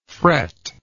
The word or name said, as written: fretless